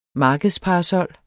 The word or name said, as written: markedsparasol